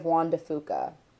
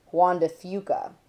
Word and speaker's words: Juan de Fuca: The racers endured formidable challenges in stage 1 of the race, while crossing the Strait of Juan de Fuca, or, if you're a local, you probably pronounce it as Juan de Fuca.